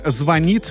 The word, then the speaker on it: звонит: В ближайшем будущем ударение в слове «звонит» может измениться и переместиться на первый слог.